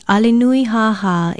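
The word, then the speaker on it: Alenui Haha (ʻAlenuihāhā): Alenui Haha is spelled Okina A-L-E-N-U-I-H-A-H-A with kahakoa stress marks over the last two vowels. Say it, Alenui Haha.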